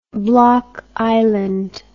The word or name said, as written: Block Island